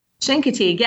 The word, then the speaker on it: Chincoteague: Now tell me a little bit about, now you're going to have to get maybe correct my pronunciation, Chincoteague? – Chincoteague, yes. The locals, it sounds like an SH, the locals pronounce it that way.